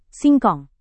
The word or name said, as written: singkong